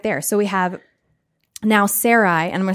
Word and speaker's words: Sarai: Sarai.